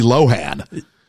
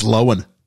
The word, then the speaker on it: Lohan: I also want it to be Lindsay Lohan. – Is it not? – It's Lohan. – Is it? – It's Lohan, yeah. – Oh, I want it to be Lohan.